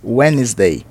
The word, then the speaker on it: Wednesday: Apesar de nós termos o D na escrita, o D é mudo, então fica Wednesday.